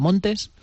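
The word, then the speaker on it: Montès: Y entonces, a raíz de ahí, se cambió el nombre, se puso el nombre artístico de Lola Montes, con ese acento grave que tienen los franceses para indicar que la S pronuncia.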